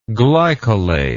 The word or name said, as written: glycolate